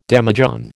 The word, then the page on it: demijohn: American English